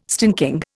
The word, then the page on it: stinking: Indian